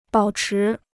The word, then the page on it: 保持: bǎo chí